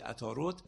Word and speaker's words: عطارد: عطارد که به عربی عطارد تلفظ میشه، به فارسی عطارد، در ستاره شناسی قدیم ستاره دبیران و نویسندگانه.